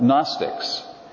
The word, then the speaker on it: Gnostics: One is a group of false teachers known as Gnostics, and that's spelt with a G. The G is silent. Gnostics.